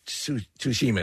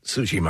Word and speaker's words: Tsushima: Tsushima, it's called Tsushima. I'm trying to pronounce it the right way, but it's the same thing, Preston.